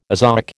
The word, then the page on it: azoic: American English